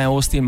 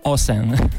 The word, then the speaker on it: Austin: Che tra l'altro, se volete, giusto per puntualizzare, per gli americani non è Austin ma è Austin.